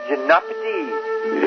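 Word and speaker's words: Gymnopédie: Prosati Gymnopédia. Did I pronounce it correctly? – Gymnopédia. – Yes.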